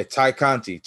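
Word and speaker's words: Tay Conti: Did I say her name right? I don't want to, like, fuck shit up. Yeah, Ty Conti. I put an A there.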